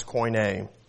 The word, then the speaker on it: Koine: Koine. And that is pronounced koi as in don't be coy and nay like a horse. That's how you pronounce Koine.